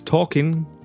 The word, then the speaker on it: talking: Ah, this is a pronunciation issue. Walking. Don't say that little. You don't need to. Walking. Similar ones. Talking. No l pronounced in it. Written, yes. You write the L, the consonant, is there, but not pronounced.